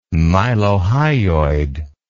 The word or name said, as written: mylohyoid